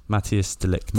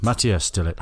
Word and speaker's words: Matthijs de Ligt: Matthijs de Ligt. – Matthijs de Ligt. – Matthijs de Ligt. How is that Matthijs? M-A-T-T-H-I-J-S.